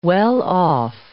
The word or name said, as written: well-off